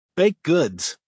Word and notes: baked goods: American English

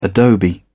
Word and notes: adobe: British English

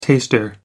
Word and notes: taster: American English

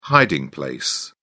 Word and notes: hiding place: British English

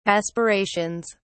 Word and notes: aspirations: British English

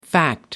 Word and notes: fact: American English

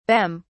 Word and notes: bem: British English

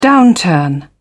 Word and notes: downturn: British English

